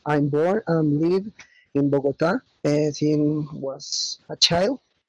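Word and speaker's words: Bogotá: Bogota? – The last A has accent, Bogota. – Ah, Bogota.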